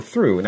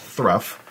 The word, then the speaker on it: through: You know, people can correct me if they want, but you look at it and you go through. Now, I know that when T-H-R-O-U-G-H isn't pronounced thruff or throw. I know it's pronounced through, but that's because I speak English and I learned that.